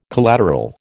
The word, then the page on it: collateral: American English